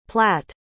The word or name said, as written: platte